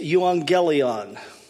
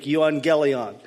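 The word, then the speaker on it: euangelion (εὐαγγέλιον): What I did was I just phonetically translated it into English, and this is how the Greek word would sound. It's euangelion. That's what the gospel is in Greek, euangelion.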